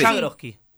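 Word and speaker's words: Schargrodsky: Está bien, le metí la D un poco pronunciada en exceso. – Hay unas consonantes que... – La D la transformamos en una S. – Exactamente. – Shargrodsky. – Exacto, evitamos la D.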